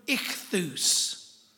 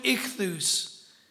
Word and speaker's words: ichthus: That's what ichthus is intimating. Okay? Jesus Christ, okay, ichthus, God's Son, and the S sound at the end, the thus, Savior.